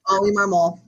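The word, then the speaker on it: Oli Marmol: And they brought in Ali Marmol. That is correct. That's how you say it, right? Ali Marmol, not Oliver? – Straight up Ali, like Oliver. – Okay. – Ali Marmol.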